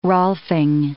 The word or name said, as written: Rolfing